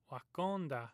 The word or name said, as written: Wakonda